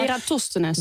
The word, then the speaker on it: Eratosthenes: Die man, dat was dus, moet ik even kijken of ik het heel goed zeg, Eratosthenes. – Ik denk dat daar de klemtoon ligt, inderdaad. Maar Eratosthenes.